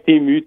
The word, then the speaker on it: Temu: Ah, je suis pas sûr qu'on le prononce comme il faut, mais ça s'appelle Temu. – Ouais, Temu, T-E-M-U.